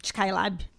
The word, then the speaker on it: Skylab: Você falou do Rogério Scarlab? Skylab. É, falei certo? É, é, é, Skylab.